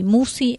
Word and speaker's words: Musci: El apellido se escribe SC. Marisa Musi, Musi, M-U-S-C-I. Esto lo dije porque me preguntan si es con doble S o con doble C y no es ninguna de las dos.